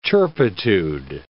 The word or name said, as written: turpitude